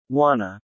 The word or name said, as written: wanna